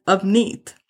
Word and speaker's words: Avneet: My name is Avneet. That's how you say it. Yes, it's pronounced with an A. But, or it's written with an A, but it's actually my name is Avneet, or Neetu, not Neetu, not Avneet.